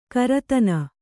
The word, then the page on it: ಕರತನ: karatana